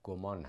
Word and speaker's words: گمان: همونطور که شما گفتید واژه زن با تازا یه لفظ عربی هست، معادل اون در فارسی گمان هست، که البته بعضی ها گمان میگن، ولی خب ظاهرا درست تلفظ درستش گمان هست.